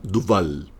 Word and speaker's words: Duval: El premio Nobel alternativo esta semana es para la poeta de Brasil, Camila Duval. Bueno, se escribe Dovalle, no sé por qué se pronuncia Duval, según Javier se pronuncia Duval, pero bueno.